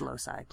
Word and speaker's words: loci: Yes, I am impressed that you used loci. – I make sure I'm pronouncing it correctly. – You didn't. It's loci. – Loci. – That's plural of locus. L-O-C-U-S, which is the cause.